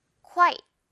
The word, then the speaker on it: quit: 너무 처음부터 quit 이렇게 가려고 하지 말고요. 쿠로 시작해서 조금 천천히 quit 이렇게 갑니다.